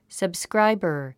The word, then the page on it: subscriber: səbskráibər; サブスクライバァ